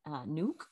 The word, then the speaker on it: Nuuk: It's Nuuk. It's N-U-U-K, Nuuk.